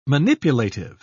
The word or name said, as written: manipulative